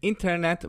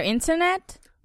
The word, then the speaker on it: İnternet: Is there internet? – Right, because we've got internet, which is a cognate. It's spelled exactly the same in Turkish, although you guys pronounce it. – İnternet. – İnternet. So very Turkified.